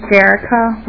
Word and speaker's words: Jerrica: My name is Jerrica Walker. My first name is spelled J-E-R-R-I-C-A.